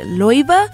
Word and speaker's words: Laufey: Coming up, music from an artist whose name is spelled L-A-U-F-E-Y, but you're supposed to pronounce it traditionally in what I believe is the Icelandic way. Is it Loiva? Laufey or Loiva?